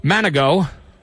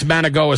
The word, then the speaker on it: Manigault: I think it's Manigo is how you say it. Manigault. – Okay.